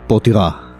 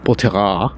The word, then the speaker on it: Poitiers: Como hicieron unas 70 veces así porque se escribe Poitiers. Potira. Potirá.